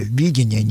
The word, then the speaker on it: видение: Я подчеркну ударение на первом слоге. Видение, не видение.